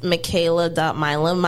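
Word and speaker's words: Mckayla Milam: So on Instagram, it's Michaela.Milam.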